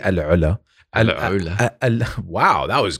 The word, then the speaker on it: AlUla: Yeah, so we drove through there and we did do the drive to Alula, which I know I that is a tough one to pronounce. – You, but, but you, you got it just right. Alula.